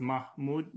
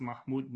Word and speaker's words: Mahmoud: My name is Mahmoud Noor. I know sometimes people call me Mohammed. Technically, it's Mah, M-A-H, and then Mood, M-O-O-D, if you wanted me to pronounce in the phonetical way. So it's Mahmoud Noor.